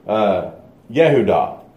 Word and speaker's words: Yehudah: So Jacob, as we said, is going to have a son, and his name's going to be Judah, or Yehudah is how it's pronounced in the Hebrew.